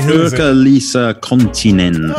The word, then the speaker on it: Sirkka-Liisa Konttinen: Sirkka-Liisa Konttinen.